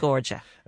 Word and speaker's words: Medjugorje: Medjugorje.